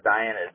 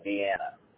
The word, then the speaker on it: Dienna: It says Diana, that should say Deanna. – How do you spell that one? – D-I-E-N-N-A. – Ah, like, okay, V-I. More of, like the capital of Austria, Vienna? But this is in West Virginia. – Yeah. – Okay, yes, I found it. – V-I-E-N-N-A.